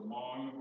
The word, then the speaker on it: Hmong: It's properly pronounced the Hmong, but I want you to remember the H.